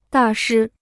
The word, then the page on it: 大师: dà shī